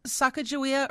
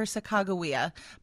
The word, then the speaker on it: Sacagawea: Sacagawea or Sacagawea. Both are acceptable pronunciations of her name.